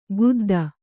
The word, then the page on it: ಖುದ್ದ: Kannada; khudda